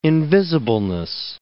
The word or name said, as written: invisibleness